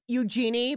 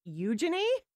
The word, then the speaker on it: Eugenie: You want to say Eugenie, but it's actually pronounced Eugenie.